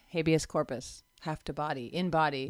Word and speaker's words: habeas corpus: how do you pronounce it? Habeas corpus, habeas, habeas.